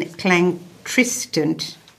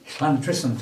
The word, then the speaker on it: Llantrisant: Today, Clark was still working as one of the eight coin designers at the Royal Mint in Llantrisant, I'm sure I haven't pronounced that right, in South Wales. Sorry, what is it? – Llantrisant.